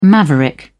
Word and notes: maverick: British English

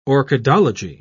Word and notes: orchidology: British English

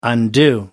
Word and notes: undue: American English

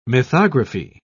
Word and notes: mythography: British English

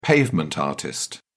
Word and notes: pavement artist: British English

